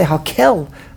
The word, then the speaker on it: HaKel: As I say HaKel, I pronounce Hashem's name with a kuf.